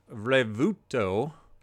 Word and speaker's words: Revuelto: So it is spelled R-E-V-U-E-L-T-O. Now, I'm going to see if I can play sound from their actual website so you can see how they pronounce it, and as well as the dictionary, online dictionary. So here we go. This is how they pronounce it. Let's see if I can see the play button here. Here we go. So I heard Revuto. I hope that's what you heard.